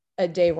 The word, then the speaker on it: Addai: The actress's name is Cynthia Ade Robinson. So I might be pronouncing it. Ade? – I might be pronouncing that wrong, spelled ADDAI,